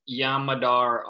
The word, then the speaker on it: Yam Madar: I think he's 6'3", 6'4", but it's – I believe his name is Yam Madar. Is that how you say it? – Yam Madar, yeah.